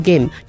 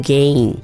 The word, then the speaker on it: gain: 然后 gain is spelled G-A-I-N，一样是长母音 gain、而且后面是 N 不是 M、所以呢你的发音如果不对的话，很多小朋友都会故意说 No pain no gain、 No pain no game，就是没有笔就没有游戏、不是这个意思、所以你的发音如果不对很容易被人家误会。好， No pain no gain、 OK，大朋友小朋友有没有记起来了呢？